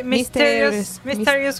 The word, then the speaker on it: Mysterious: Incluye éxitos como One, Mister, Mysteries, Mister se pronuncia? mysterious, Ways